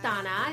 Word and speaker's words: Dana: My name is Dana. It's spelled D-A-N-A. Last name is A-S-A-D-I.